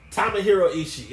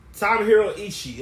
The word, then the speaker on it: Tomohiro Ishii: Tomohiro Ishii.